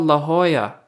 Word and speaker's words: La Jolla: That's called Spanish. They pronounce the J's like H's, and they pronounce a double L like a Y. So it's La Jolla.